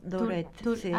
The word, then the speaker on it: Tourette: Tourette, así se pronuncia.